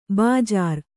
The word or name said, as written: ಬಜ್ಜರ